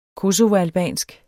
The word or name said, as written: kosovoalbansk